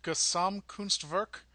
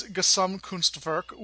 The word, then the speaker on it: Gesamtkunstwerk: but I believe it's pronounced Gesamtkunstwerk. – Sounds good to me.